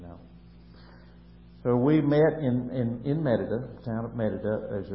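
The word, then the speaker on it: Mérida: Well, we got to Merida, M-E-R-I-D-A, Merida, pronounced Merida, Mexico, on Wednesday in the evening, about 6 o'clock, I guess, that evening.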